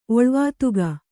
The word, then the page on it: ಒಳ್ವಾತುಗ: Kannada